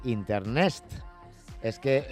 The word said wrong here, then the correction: Internext: Sí, exacto, Internext. O sea, se escribe efectivamente Inter, N-X-T, como si... – Exacto. Sí, como sí, Internet, segunda, pero sustituyendo la segunda E por una X. – Exacto, es Inter, N-X-T. – Bueno, bueno. – Y se pronuncia Internext, exacto.